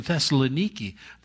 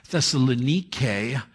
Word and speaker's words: Thessaloniki: Thessalonike is how it is pronounced in the original language, or Thessaloniki, as some call it today.